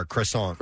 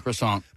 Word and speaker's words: croissant: Throw an R in there, a hard R, croissant. – Croissant. – But it's more like a W, croissant.